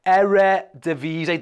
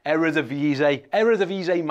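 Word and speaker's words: Eredivisie: Django's telling me how you say it. Eredivise, that's right. Eredivise, Mike.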